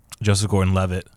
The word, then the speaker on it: Joseph Gordon-Levitt: which also is funny because they have Joseph Gordon-Levitt.